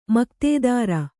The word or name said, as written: ಮಕ್ತೆದಾರ